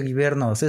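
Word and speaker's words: guivernos: Givernos, por favor. Te había dicho que eran Givernos. Se pronuncia Givernos. Es G-U-I. Es B, ¿no? No, es que en inglés es W-Y-V-E, ah. – pero en español es G-U-I-V-E.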